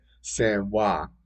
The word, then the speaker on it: San Joaquin: Yeah, San Joaquin.